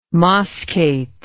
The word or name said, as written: moschate